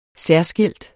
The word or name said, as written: særskilt